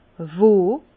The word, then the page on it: wo?: Bas-Rhin; Herrlisheim